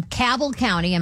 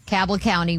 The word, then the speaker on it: Cabell County: Cabell County. Am I saying that right? – Yeah. – Cabell County, West Virginia, uh, allegedly kicked students off the school bus in the middle of severe flooding.